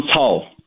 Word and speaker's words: Cao: Is it Pastor Cao? – Yeah, Pastor John Cao.